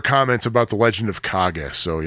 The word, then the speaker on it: Kage: By the way, apparently Kage is not pronounced Cage, but rather Kage.